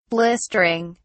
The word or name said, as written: blistering